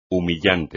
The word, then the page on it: humillante: espagnol